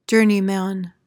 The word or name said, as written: journeyman